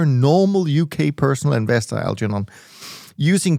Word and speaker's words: Algernon: I'm sure pronouncing that right, but anyways, um, and uh, so this is this is one of those very old-fashioned British names that I think you should pronounce it Algernon. Algernon.